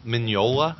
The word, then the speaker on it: Mignola: Mike Mignola came up and said that. – Is that how you say his name? – Mignola, yeah. Yeah, the G is silent.